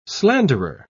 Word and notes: slanderer: British English

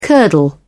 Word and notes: curdle: British English

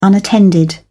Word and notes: unattended: British English

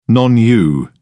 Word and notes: non-u: British English

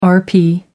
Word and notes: RP: American English

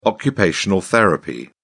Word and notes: occupational therapy: British English

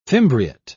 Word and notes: fimbriate: British English

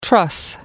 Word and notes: truss: American English

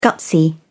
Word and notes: gutsy: British English

